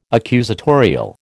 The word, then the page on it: accusatorial: American English